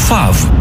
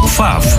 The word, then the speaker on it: FAW: ФАВ. Пишется F-A-W. Название марки образовано от английского First Automotive Works. Первая автомобилестроительная компания. ФАВ.